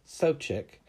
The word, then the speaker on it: Sobczak: That's Mike Sopcich, and his last name is spelled S-O-B-C-Z-A-K. And let me correct myself. It's Mick Sopcich, M-I-C-K, last name S-O-B-C-Z-A-K.